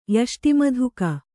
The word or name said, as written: ಯಷ್ಟಿಮಧುಕ